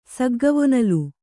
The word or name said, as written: ಸಗ್ಗವೊನಲು